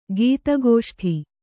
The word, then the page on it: ಗೀತಗೋಷ್ಠಿ: gīta gōṣthi